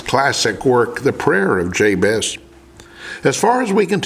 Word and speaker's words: Jabez: In Hebrew, pronounced Yabetz, the word Jabez means pain.